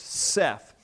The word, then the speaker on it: saith: And I came up and I opened it up and I showed him that that old English word saith is to be pronounced Seth.